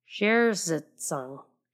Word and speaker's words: Zersetzung: Zersetzung.